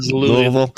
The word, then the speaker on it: Louisville: Is it Louisville or Louisville? – Louisville. It's always Louisville.